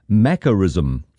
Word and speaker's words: macarism: And to the word now, this morning it is Macarism. Let me spell it, M-A-C-A-R-I-S-M. Macarism, please, it contains the few letters that make up Mac.